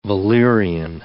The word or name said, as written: valerian